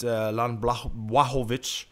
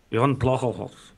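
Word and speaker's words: Jan Błachowicz: We've got Dominic Reyes coming up against Jan Blachowicz. – No, you have to say it like Jan Blachowicz.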